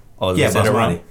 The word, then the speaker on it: basmati: Rohit's over here eating basmati all day long. – Basmati? – Basmati. – Basmati? – No, it's basmati, just like the Taj Mahal.